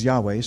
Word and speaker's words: Yahweh: So his name, in the Hebrew, the closest pronunciation we can come up with is Yahweh.